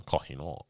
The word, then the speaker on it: Kohinoor: Come on, Kohinoor. right? And like apparently, Are you like pronouncing that right? Yeah, yeah. Yeah, go on.